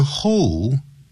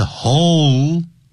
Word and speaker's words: hole: 如果你念成 hole，那我马上就听出来你没有把舌头翘上来，翘上来的声音是 hole，没有翘上来的是 hole。那个 hole 的时候，你看咱们中国人多少人会念成 hole。那就变成不是动物，那是锄头。